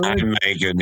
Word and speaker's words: Nijmegen: Nijmegen. Not sure how you pronounce it in Vorburg. – Nijmegen, yeah.